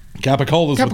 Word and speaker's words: capicola: Capicola is what the meat is.